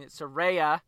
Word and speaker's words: Saraya: So, more women's talk here as you finally got the face-to-face between Saraya, I said it right this time, and Dr. Britt Baker.